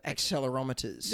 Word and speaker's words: accelerometers: There were 40 healthy adults without sleep disorders and their dogs, and the adults and dogs wore accelerometers. How do I say that? – Accelerometers.